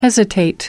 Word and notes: hesitate: American English

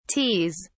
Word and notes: teas: British English

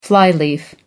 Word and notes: flyleaf: American English

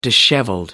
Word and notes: dishevelled: American English

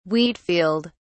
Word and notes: wheat field: British English

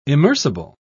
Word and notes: immersible: British English

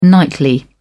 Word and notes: nightly: British English

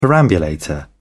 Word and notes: perambulator: British English

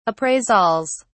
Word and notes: appraisals: British English

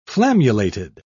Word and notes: flammulated: British English

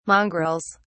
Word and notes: mongrels: British English

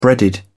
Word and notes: breaded: British English

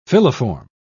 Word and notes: filiform: British English

